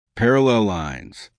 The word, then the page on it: parallel lines: American English